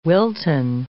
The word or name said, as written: Wilton